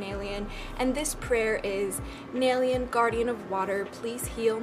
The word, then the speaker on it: Naelyan: It is spelled N-A-E-L-Y-A-N, although it's pronounced N-A-I-L-Y-O-N, so like Nalian, but it's spelled like Yan, Nalian, but you don't say it that way, you say it Nalian.